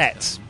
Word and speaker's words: et: Et is and. – Et. I think it's et. I was right. You don't pronounce the consonants at the end of words.